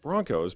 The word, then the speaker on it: Bronchos: Broncos, but it's spelled Bronchos.